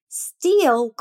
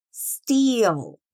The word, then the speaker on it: steal: Steal com E-A é o verbo roubar. Tem a pronúncia mais alongada, olha só. Steal. Repeat. Steal. Steal. Still, escrito S-T-I-L-L, significa ainda.